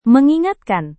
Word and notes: mengingatkan: Indonesian

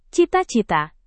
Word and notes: cita-cita: Indonesian

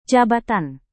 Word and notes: jabatan: indonesian

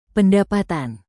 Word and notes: pendapatan: Indonesian

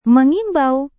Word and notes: mengimbau: Indonesian

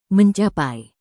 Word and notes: mencapai: indonesian

